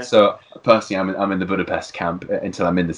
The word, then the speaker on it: Budapest: I mean, I've got one thing, which is actually, when you go there, don't be shocked if people are calling it Budapest, because that's how it's pronounced. – Proper pronunciation. But I've heard a lot of people say, from the UK and from America, say Budapest.